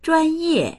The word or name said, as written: zhuan1ye4